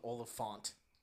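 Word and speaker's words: Oliphaunt: Yeah, that elephant thing was really cool. – Olyphant. – Come on. It's actually called Olyphant.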